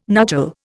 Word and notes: nuchal: Indian

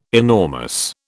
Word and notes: enormous: British English

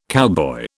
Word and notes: cowboy: British English

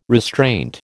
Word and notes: restraint: American English

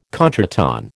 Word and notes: contretemps: American English

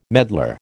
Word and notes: medlar: American English

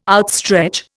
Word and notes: outstretch: Indian

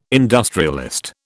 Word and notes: industrialist: British English

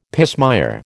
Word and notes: pismire: American English

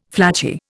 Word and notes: flattie: Indian

